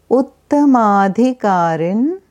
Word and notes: Uttama Adhikari: Sanskrit

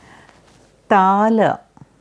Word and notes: Tal Tala: Sanskrit

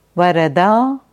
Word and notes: Varada: Sanskrit

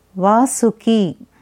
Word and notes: Vasuki: Sanskrit

